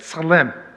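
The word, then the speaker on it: tsalem (צֶלֶם): And the word image, you say that, tsalem. That's how you say it. It's like, you know, tisk, tisk kind of thing.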